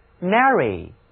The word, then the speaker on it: marry: Marry，字母 A 在这个词中读作扁的 A。